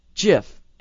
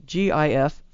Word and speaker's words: GIF: A multicolored GIF flashing the same phrase. So it's pronounced GIF, even though it's spelled GIF.